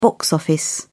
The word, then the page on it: box office: British English